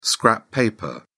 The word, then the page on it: scrap paper: British English